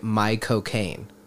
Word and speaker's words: Caine: And the way to say his name is to say my cocaine.